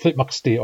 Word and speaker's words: Tlitmaqsti: Nechanoth strictly has only one consonant at the beginning of each syllable. Therefore, the syllables of Tlitmaqsti are Tlitmaqsti.